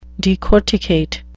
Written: decorticate

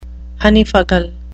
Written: honeyfuggle

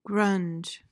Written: grunge

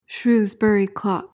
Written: shrewsbury clock

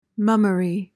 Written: mummery

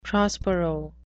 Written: prospero

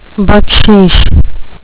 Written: baksheesh

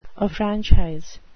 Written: affranchise

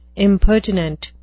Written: impertinent

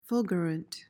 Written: fulgurant

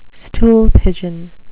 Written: stool pigeon

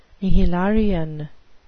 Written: nihilarian

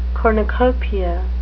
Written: cornucopia

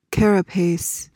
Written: carapace

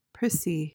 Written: prissy

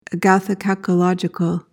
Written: agathokakological

